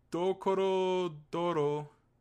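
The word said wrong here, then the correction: Dorohedoro: Doro Hedoro. – Doro Hedoro, thank you.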